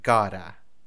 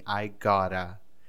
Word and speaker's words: gotta: G-O-T-T-A. G-O-T-T-A. Gora. Gora. Nossa Senhora, mas é G-O-T-T-A. Como é que você tá soltando um R aí? Não, é isso mesmo, tá? No dia a dia a gente vai pronunciar assim. I gotta.